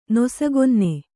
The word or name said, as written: ನಸುಕುನ್ನಿ